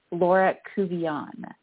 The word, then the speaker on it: Laura Couvillon: Laura Covillon.